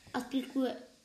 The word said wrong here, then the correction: Azpilicueta: o oh, sí. Sí, César, vamos a llamarlo César, pero es Azpilicueta.